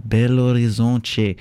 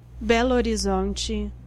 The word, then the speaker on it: Belo Horizonte: Yes, I did my research this time to pronounce it properly, and in Portuguese you would say Belo Horizonte. Wow, the internet was okay. This is how you're supposed to pronounce it. This is how a Brazilian person does. – Belo Horizonte.